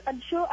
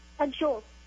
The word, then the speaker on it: Anshul: Hello, my name is Anshu. I think the answer is laundry. – Laundry? What, just a minute, what's your name again? Anshu? – Anshu. – How do you spell it? – A-N-S-H-U-L.